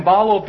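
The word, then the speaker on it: ballo: Dia is spelled D-I-A, which means through, and balo, B-A-L-L, long O, which means to throw.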